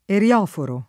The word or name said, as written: erioforo